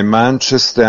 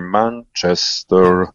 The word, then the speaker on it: Manchester: Ecco, hai così in questa maniera esaudito già la mia ulteriore richiesta di pronuncia corretta, perché Manchester e non Manchester, come dice qualcuno, per darsi un tono, evidentemente. – No, è Manchester, anche se sarebbe quasi con due accenti, Manchester.